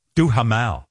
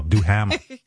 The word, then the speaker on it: Duhamel: Take that H out if you don't want me to bother with it. Yeah. Yeah. We don't believe in, I don't like silent H's. He spells it D-U-H-A-M-E-L, Duhamel, like Duhamel.